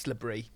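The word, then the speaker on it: LaBrie: It's Brie. It's B-R-I-E. – But it probably isn't pronounced like that. – Well, Brie is pronounced Brie. – Right, fine, it's LeBrie. – That's how Brie's spelt. – All right, yeah, it's LeBrie.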